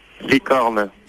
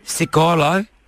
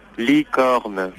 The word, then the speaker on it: licorne: Licorne. – Cicolo? – Licorne. – Nicolo? – Licorne.